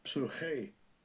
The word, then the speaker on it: psyche: Strong's G, 5590. Psuche. Psuche. – Psuche. When you look at it in the English, it looks like psyche.